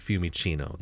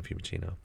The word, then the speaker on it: Fiumicino: The hotel we stayed at was called, I think I'm pronouncing this correctly, because in Italian, if a C is followed by an I or an E, it makes the ch sound, like Fiumicino, the C in Fiumicino.